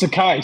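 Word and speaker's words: açaí: It's a kai, Seth.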